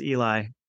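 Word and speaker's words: Ely: But even the blind man at the end of, or in the road, not at the end of the road, Eli or Eli or however we want to say his name. Who I certainly pronounce it as Eli with all its sort of biblical import.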